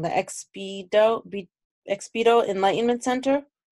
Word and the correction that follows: Expedito: Yes, it's Expedito Enlightenment Center.